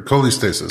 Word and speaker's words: cholestasis: And this coleostasis, is that how you pronounce it? Coleostasis? – Sure. Cholestasis. – Cholestasis.